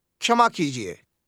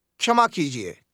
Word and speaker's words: क्षमा कीजिए: Kshama kijiye. Kshama kijiye.